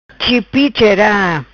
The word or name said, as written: Chipi chera